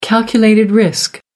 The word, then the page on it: calculated risk: American English